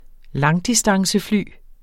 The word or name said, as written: langdistancefly